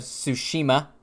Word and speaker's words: Tsushima: We're going to be talking about Ghost of Tsushima and Paper Mario. – You pronounced it right.